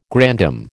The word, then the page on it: grandam: American English